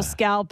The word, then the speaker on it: scalloped: you want to put the pounds, eat a couple scalloped potatoes. It's just cream. – What are you saying? Scalped potato? Scalloped. – You scalp them, buddy. – Scalloped. – Scalloped. – Scalloped. Extra syllable in there.